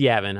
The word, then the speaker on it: Yavin: Oh, yeah, it's Yavin, I know, but I call it Yavin. – Just always think of Gavin.